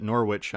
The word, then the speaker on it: Norwich: And you played four years at Norwich. Did I say that right? Because I think last time. – Yeah, you got it right.